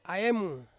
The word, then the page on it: aemu: Ansus